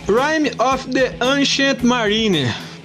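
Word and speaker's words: Rime of the Ancient Mariner: Ah, need you to want me E o Lucas pediu Rhyme of the Ancient Mariner.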